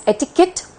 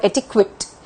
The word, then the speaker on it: etiquette: You can pronounce this as pronounce it as etiquette or etiquette, E-T-I-Q-U-E-T-T-E.